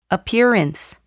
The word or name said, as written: appearance